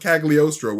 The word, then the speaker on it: Cagliostro: Agamotto and Caligari. I'm saying this name wrong. The Book of Cagliostro, which is the study of time.